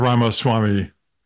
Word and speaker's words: Ramaswamy: Mr. Ramaswamy.